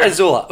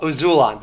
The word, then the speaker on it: Azulon: I kept missing a syllable. – No, there. Not Azula, Azulon, the fire.